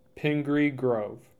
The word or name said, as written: Pingree Grove